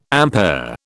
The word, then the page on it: ampere: British English